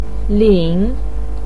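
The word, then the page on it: lin3: pinyin; tone 3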